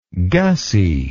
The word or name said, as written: gussy